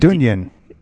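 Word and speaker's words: Dungen: If I'm pronouncing that right, Dungeon.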